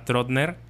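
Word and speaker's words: Trottner: Bueno, el primero de estos libros tuvo su propio book review en el capítulo pasado, que es Nadie nos vio partir de Tamara Trotner. Ahora sí lo dije bien.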